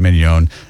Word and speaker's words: Mignon: Mignon, we was joking because I said filet mignon.